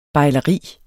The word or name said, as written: bejleri